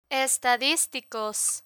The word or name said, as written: estadisticos